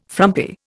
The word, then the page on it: frumpy: Indian